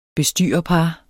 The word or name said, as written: bestyrerpar